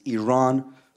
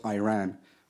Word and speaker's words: Iran: First of all, you say you support the Iranian people, but you're pronouncing our country's name wrong. Our country is named Iran, not Iran.